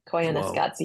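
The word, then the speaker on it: Koyaanisqatsi: It's spelled crazy. It's spelled with a K. It's Koyaa Nisqatsi.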